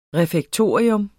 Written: refektorium

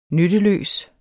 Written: nytteløs

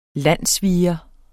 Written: landssviger

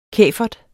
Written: kæfert

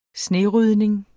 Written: snerydning

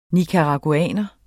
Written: NICARAGUANERE

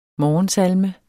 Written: morgensalme

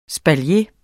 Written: espalier

